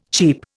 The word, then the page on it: cheep: Indian